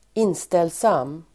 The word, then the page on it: inställsam: [²'in:stelsam:]